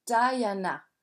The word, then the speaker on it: Diana: Avant, Charles était marié avec la princesse Diana, qui se prononce Diana, mais elle est morte, la pauvre.